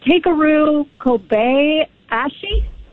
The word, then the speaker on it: Takeru Kobayashi: Like spell his name, but Takeru Kobayashi. It's Takeru, and then his last name's Kobayashi.